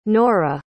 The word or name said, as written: norah